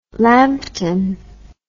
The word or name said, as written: lampton